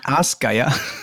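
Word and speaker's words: Ásgeir: Asgeir.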